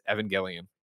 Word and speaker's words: Evangelion: Lind announced the news via his Twitter in a tongue-in-cheek video riffing on the next episode bookends of Neon Genesis Evangelion. I screwed up, right? Evangelion. What are you nerds? How do you pronounce it? – You know, you got it the first time. – Evangelion.